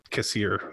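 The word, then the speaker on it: Kassir: I really respect John Cassier. That's how you say his name.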